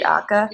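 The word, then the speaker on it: Akha: And am I pronouncing that right? Is it Akka? – Exactly. Okay. Right.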